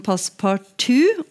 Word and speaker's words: passepartout: I ordbøkene så står eh, uttalen passepartout, og det er jo vanligvis sånn vi har laget den norske formen av fransk eh, OU, som på fransk uttales O. Så vi sier jo tur-retur, f.eks. Fra da fransk, eh, selv om det heter da eh, retour. Så sier vi, men jeg sier faktisk pass på to. Det ligger nærmere den eh, franske uttalen.